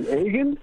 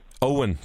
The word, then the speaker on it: Eoghan: It's spelled E-O-G-H-A-N, and then people pronounce it, I've had Egan, I've had Eggman, I've also got yogurt once as well, but it's actually pronounced Owen. But I was... – Owen, that's it, yeah. Like O-W-E-N.